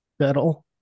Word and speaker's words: Shtetl: Okay, in the beginning, the Yiddish folktale, it's Alan Lewis Rickman played, I'm going to butcher this name, Shettle Husband. And the Shettle Wife, I don't know whether that's a town name or a... – Shettle. S-H-T-E-T-L.